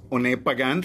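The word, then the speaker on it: Oné Pagán: My name is One Pagán. Thank you for saying it right, by the way. – Has those two accents.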